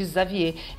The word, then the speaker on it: Xavier: E em francês se escreve Xavier, mas fala o X ali tem som de Xavier.